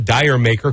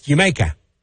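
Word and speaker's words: D'yer Mak'er: Led Zeppelin from Houses of the Holy in Jamaica on 93.3 KZOZ. I know most people call it Dyer Maker because that's how it's spelled. D-apostrophe-Y-E-R-M-A-K-apostrophe-E-R, like Dyer Maker. But in Britain, it's pronounced Jamaica.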